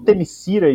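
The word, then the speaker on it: Themyscira: Igual Temisira e Temiskira, né? que a gente ouve da Mulher Maravilha. Nos filmes, por exemplo, eles pronunciam Temiskira.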